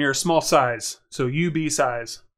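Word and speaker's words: UBeesize: Not IB Size, UB Size. Yeah. UB Size is U, the letter, B-E-E, size, like you get a small size of something.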